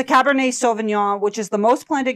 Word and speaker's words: Cabernet Sauvignon: Cabernet Sauvignon. So, did I say it wrong? – No, I think you said it perfectly.